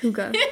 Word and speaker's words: Hygge: Never mind. It's pronounced Hygge.